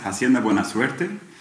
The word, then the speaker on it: Hacienda Buena Suerte: Genau, die Hacienda heißt Hacienda Buena Suerte.